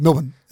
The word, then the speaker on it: Melbourne: Whereas if you spelt Melbourne the way we say it in Australia, it would be M-L-B-N, I reckon. Melbourne. That's about how we say it.